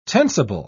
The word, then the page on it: tensible: British English